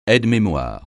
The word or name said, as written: aide-mémoire